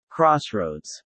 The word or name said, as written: crossroads